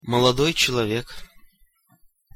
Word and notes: molodoj celovek: Russian

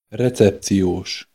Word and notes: recepcios: Hungarian

